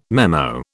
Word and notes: memo: British English

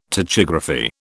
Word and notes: tachygraphy: British English

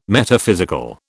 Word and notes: Metaphysical: British English

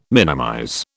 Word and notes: minimize: British English

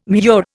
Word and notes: murex: Indian

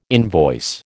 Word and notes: invoice: American English

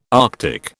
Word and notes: Arctic: British English

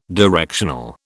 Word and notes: directional: British English

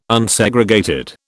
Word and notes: Unsegregated: British English

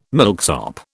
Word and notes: milksop: British English

